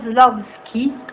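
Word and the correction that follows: Shklovsky: Sì, Sklovsky, vai. – Sklovsky. – Non parlo bene il russo. – Perché non riesco a... – Sì, però è Viktor Sklovsky.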